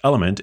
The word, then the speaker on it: LMNT: Element, spelled L-M-N-T, but pronounced Element, is what I like to call a power powder packet.